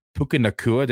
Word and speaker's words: Puka Nacua: Puka Nacua. Did I say that right? – Yeah. Out of BYU.